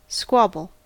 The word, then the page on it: squabble: American English